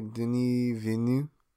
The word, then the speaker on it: Denis Villeneuve: I say Denis Villeneuve.